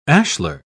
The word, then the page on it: ashler: British English